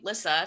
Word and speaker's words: Lyssa: And I do want to apologize, because my name, Lissa, spelled L-Y-S-S-A, everybody mispronounces it.